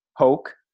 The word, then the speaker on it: Hoch: And just to give a little bit of a background, and you can obviously add to this, but Bryan Hoch rhymes with Coke. Remember that, not the other way that it might look on print.